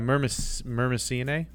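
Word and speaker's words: Myrmeciinae: Is that a soft C, do you think? Myrmecyinae? – Yeah, Myrmecyinae. Whenever they put those two together, like I'll take one pronunciation of the vowel for the first one and then just pick the unstressed one for the second.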